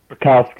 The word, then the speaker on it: Bukauskas: We have Modestas Bukauskas. I still can't say it. He even practiced saying it, and I still can't say it. UFC light heavyweight. Modestas Bukauskas. – Welcome. Bukauskas. Is that right? – Bukauskas.